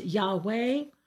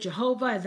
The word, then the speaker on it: Yahweh: Sometimes it's pronounced Yahweh, or in English, they made it Jehovah. That's not exactly correct.